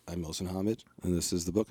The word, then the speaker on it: Mohsin Hamid: And he pronounced his own name Mohsin Hamid.